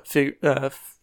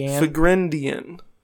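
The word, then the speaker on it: Figrin D'an: It is D apostrophe A N, so probably like Deanne. – Yeah, Figrindian. Figrindian.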